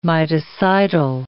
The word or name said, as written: miticidal